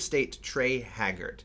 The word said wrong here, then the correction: Hargett: Har- Hargett, not Haggard, Hargett, who's the um, I think I'm pronouncing his last name.